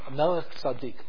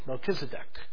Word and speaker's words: Melchizedek: You know the name from Melech Tzaddik, Melchizedek, is how it's mispronounced in English.